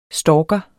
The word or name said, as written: stalker